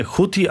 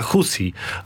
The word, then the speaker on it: Huti: Monitoring bliskowschodni, panie ambasadorze, podpowiada mi, że nie Huti, a Husi, ale to już jest taka wymowa, bardzo bym powiedział, ortodoksyjna w tym momencie, bo przyjęta jest w naszych mediach nie tylko wypowiedź Huti, prawda, ale to już tak...